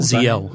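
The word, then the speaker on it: Ziyal: talk to Zayu. I can never pronounce her name. Zayel? – Ziel.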